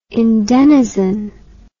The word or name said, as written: endenizen